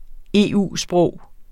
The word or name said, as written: EU-sprog